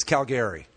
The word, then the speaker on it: Calgary: You got to pronounce it right. It's Calgary. Come on.